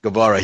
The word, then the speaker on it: Guevara: Guevara. – Is that how you pronounce his name? – Guevara.